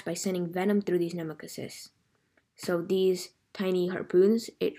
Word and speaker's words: nematocysts: Nematocysts.